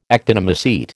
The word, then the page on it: actinomycete: American English